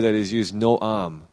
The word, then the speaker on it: Noam: And the Hebrew word, no idea how to pronounce it, but it's N-O-A-M, Noam.